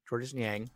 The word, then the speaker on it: Georges Niang: Yeah, their starting lineup is – Georges Niang, is that my saying his name right? Georges Niang.